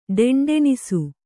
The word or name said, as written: ಢೆಂಢೆಣಿಸು